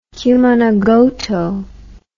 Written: cumanagoto

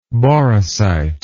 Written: boracite

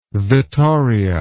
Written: vittoria

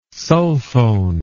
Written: sulfone

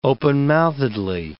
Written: openmouthedly

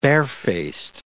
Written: barefaced

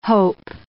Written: hope

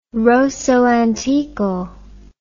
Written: rosso antico

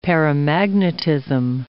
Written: paramagnetism

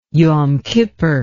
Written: yom kippur

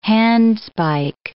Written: handspike

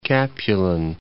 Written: Capulin, Mount

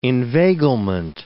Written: inveiglement

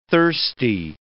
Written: thirsty

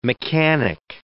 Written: mechanic

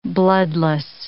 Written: bloodless

